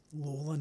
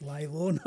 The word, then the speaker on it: Lawler: Peter Lawler, and that is how you pronounce the name, Lawler, not Laylaw, not like the suburb.